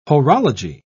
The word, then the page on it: horology: British English